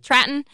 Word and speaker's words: Traton: Truck maker Traton, is that how you pronounce it? – Traton